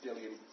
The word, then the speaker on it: Bdellium: Yeah, so how do you pronounce it? – Bdellium. – Just, you ignore the D. – Bdellium. – You do put the B in there too? – Bdellium.